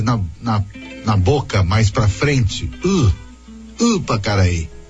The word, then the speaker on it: Ipacaraí: Porque ele não disse Ipacaraí. Ele puxa por ser Y, por ser Y, ele puxa para aquela pronúncia do Guarani.